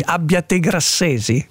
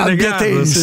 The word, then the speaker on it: abbiatensi: Abbiate Grassesi? – Non lo so, però io ogni volta...